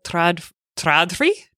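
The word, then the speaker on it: Trådfri: And basically, I have dumped my IKEA Tradfri. How is it supposed to be said? – I think you said it correctly.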